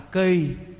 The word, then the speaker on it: kỳ: Đúng nghĩa cái chữ cầu này thưa quý vị á, đúng của nó là đọc là kỳ, theo cái từ chữ Hán là chúng ta viết là chữ kỳ chứ không phải chữ cầu, là sau này chúng ta đọc trại thành chữ cầu.